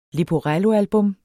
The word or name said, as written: leporello